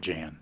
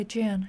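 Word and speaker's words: Jaan: Hank told me that they pronounce it Jan. – Okay, Jan.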